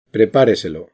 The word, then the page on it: prepareselo: acento lexical; castilla